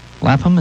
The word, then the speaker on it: Lapham: Lapham, is that how you say his name? – I believe so.